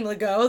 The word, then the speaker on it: Legault: I'm like, Lego looks like Legault, and they find it immediately. – Yeah. – Because I, like, want them to know how to say it.